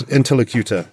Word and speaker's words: interlocutor: interlocutor. That's the one. They were in favour... – You're putting the emphasis on the wrong syllable. – The emphasis, interlocutor. Yeah, interlocutor.